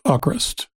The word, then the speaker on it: AVKRVST: Hawkrust, this is the new band out of Norway that I was butchering their name all of season 3. It's English spelling A-V-K-R-V-S-T, but it's pronounced Hawkrust.